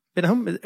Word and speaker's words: Bennahum: Benahum, am I pronouncing that correctly? – Benahum, sounds good.